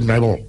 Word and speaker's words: Neville: Es Neville.